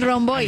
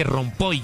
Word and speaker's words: Romboy: ¿Rompoy o Romboy? – Bueno, ¿cómo se dice ahí? – El Romboy. – Algunos dicen que Rompoy, yo no sé de dónde sacaron.